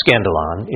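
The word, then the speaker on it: skandalon (σκάνδαλον): The Greek word that is translated offense in Matthew 18 is pronounced skandalon in the Greek.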